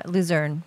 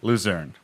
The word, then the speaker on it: Luzern: Let's see, this one is coming to us from Monica Lapasse, Luzon, Switzerland. – Ooh. – Am I saying that right? – Yeah, Luzern. – Luzern.